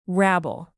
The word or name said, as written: rabble